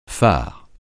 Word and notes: fard: francais

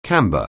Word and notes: camber: anglais

